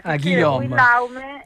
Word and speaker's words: Guillaume: Guillaume.